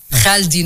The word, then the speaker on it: Khaldi: Khaldi, nous on dit. – D'accord. – Toujours, il y a le kha aussi, le a que vous. – Oui, c'est une lettre qui ne se prononce pas en français.